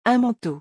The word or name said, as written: Un manteau